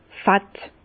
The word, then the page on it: fett: Bas-Rhin; Herrlisheim